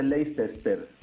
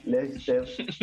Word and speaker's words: Leicester: Sí, bueno, se dice Leicester, para que, para usted también el asado no lo diga mal. Leicester, entonces lo pronunciamos bien. Y también. – Leicester, Leicester. Leicester. Acento británico, Leicester. Sí, eso.